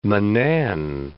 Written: Grand Manan Island

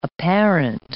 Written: apparent

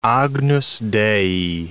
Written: Agnus Dei

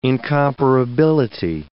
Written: incomparability

